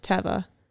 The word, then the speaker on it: Teva: Second of all, yeah, no, I think I saw it from the Teva brand that it's pronounced Teva.